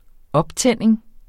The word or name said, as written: optænding